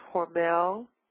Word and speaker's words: Hormel: It's pronounced Hormel, H-O-R-M-E-L,